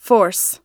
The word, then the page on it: force: American English